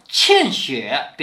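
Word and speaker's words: 茜雪: 欠雪便过去了。这个欠雪的欠啊，是多音字，也可以念西。作为人名要念欠。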